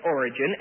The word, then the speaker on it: Origen: Now, what we will do then is conclude with Origen, and that is spelled O-R-I-G-E-N, Origen.